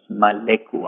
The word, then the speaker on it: Maleku: Maleku, I'll spell it for you. M-A-L-E-K-U, Maleku.